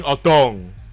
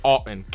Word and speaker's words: Otton: I was saying Otong. It's Otton. Cade Otton. Hi, I'm Cade Otton. – The gong is back. – Hi. How are you? – The gong is back. – Cade Otton, nice to meet you.